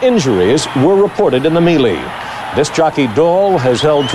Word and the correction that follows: melee: Melee? – Mm-hmm. Melee. – I mispronounced that word because I hadn't written the script myself, and it came...